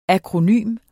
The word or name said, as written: akronym